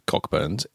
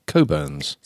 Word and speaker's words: Cockburn's: Not as Coburn's. – It is written Cockburn's. – But it isn't Cockburn's. It is Coburn's. – So why does it say C-O-C-K Burns?